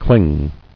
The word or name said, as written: Clingy